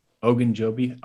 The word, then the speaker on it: Ogunjobi: The last defensive lineman I want to touch on is Larry Ogunjobi. Probably butchered that last name, but is he someone that the Raiders need? – Ogunjobi. – All right. Eventually I'll get it right.